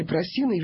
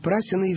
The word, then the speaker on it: Прасины: Там были Просины и Венеты. Некоторые энциклопедии дают ударение «Прасины и Венеты». Я не могу это принять и переделаться. Все мои учителя говорили про «Просины и Венеты».